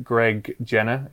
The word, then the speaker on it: Greg Jenner: It's Greg Jenner. Is that right, Sam? Am I getting the name right? – It is. – There? – Yep. – Greg Jenner, yeah.